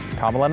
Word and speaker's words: Kamala: Kamala Namala. You'll remember how to pronounce the name, and you'll remember you don't like her very much.